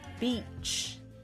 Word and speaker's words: beach: Beach E 长元音，一个是 Bitch 短元音、 yeah.